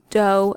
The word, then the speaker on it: Doe: the scene where she teaches the kids doe, a deer, a female deer. It's pronounced like the music note, doe.